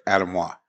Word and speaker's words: Anemoi: The press release when it first came out had it as Anamois. And when we interviewed Hochi Blanco at IPCPR last year, he said Anamois.